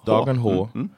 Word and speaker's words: Dagen H: Okay, so Dagen H, am I saying that right? – Yeah, Dagen H.